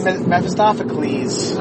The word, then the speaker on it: Mephistopheles: Did you say Mephistopheles? – Mephistopheles. – Oh. Yeah, you had to see. – Because it's Greek. He's a Greek.